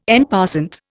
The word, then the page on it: en passant: Indian